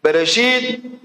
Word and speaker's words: Bereshit: Y se pronuncia, como está visto Bereshit, Bara, Elohim, Et, Hashamayim, Beet, Haretz.